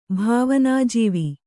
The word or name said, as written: ಭಾವನಾಜೀವಿ